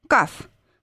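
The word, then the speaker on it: Каф (כ): Каф. К или хрипящая Х, если в середине слова.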